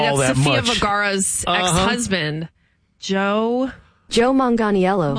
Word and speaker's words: Manganiello: I tried to, here's how we pronounce it. – Joe Manganiello. – Manganiello. I've never heard it said like that.